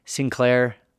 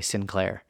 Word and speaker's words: Sinclair: If you guys hear me, and probably Sinclair could be tuned up just a little bit. Yeah, I think we pronounce it a little bit differently here in the U.S. versus Scotland. I hope I'm coming close. So it's more of like a sink sound at the beginning of it instead of like a, in here in the U.S.